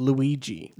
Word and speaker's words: Luigi: Now, I do know how to pronounce that one. It's Luigi.